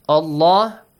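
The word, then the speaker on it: الله: Allah, lam is pronounced with a heavy sound.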